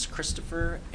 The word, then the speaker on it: Christopher: My name is Christopher Aaron Pauly. It's C-H-R-I-S-T-O-P-H-E-R.